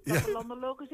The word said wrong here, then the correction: Plattelandologisch Instituut: Instituut. Plattelandologisch Instituut. Drie keer oefenen en je hebt oh, het.